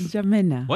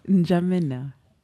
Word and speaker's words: N'Djamena: No, it's not N'Djamena. – What is it? – It's N'Djamena. – What? – N'Djamena. – Huh? What, the D is silent? – Yes. – Who told you? – It's so silent.